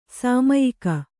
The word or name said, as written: ಶಮ್ಯಾಕ